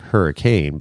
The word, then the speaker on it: Hurricane: It looks like Hurricane, but it's pronounced Hurricane.